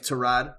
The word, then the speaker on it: Tyrod: Dude, Mikey, to your point, pronounce his name Tyrod. – Is it Tyrod? – Yeah, he said it on Hard Knocks a few years back. Yeah. His name's Tyrod.